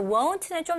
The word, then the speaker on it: won't: So won't는 좀 더 o 발음이 들어가고 여기 want 같은 경우는 입술을 쓰는 것보다는 턱이 내려가는 느낌으로 want, want.